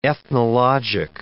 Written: ethnologic

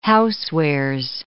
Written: housewares

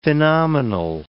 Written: phenomenal

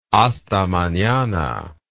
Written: hasta manana

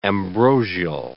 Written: ambrosial

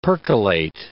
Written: percolate

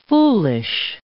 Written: foolish

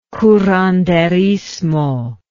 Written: curanderismo

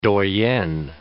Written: doyenne

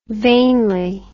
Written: vainly

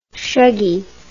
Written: shuggy

